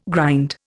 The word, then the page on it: grind: Indian